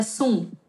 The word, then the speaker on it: Song: É, somos a família Sun, né? Se escreve song, né? Como se fosse música em inglês, mas somos a família. A pronúncia é Sun.